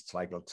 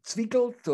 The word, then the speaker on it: Zweigelt: And then all of a sudden I got calls from customers telling me, damn, you know, I want some more of this Zweigelt, or, you know, it's very difficult to pronounce Zweigelt because the E and the I in the German language is pronounced the opposite way.